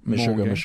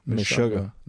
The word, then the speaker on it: Mississauga: Mississauga.